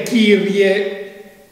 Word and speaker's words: Kyrie: Noi adesso nelle nuove messe dobbiamo invece dire Kyrie eleison. Kyrie è la pronuncia del VI-VII secolo dopo Cristo,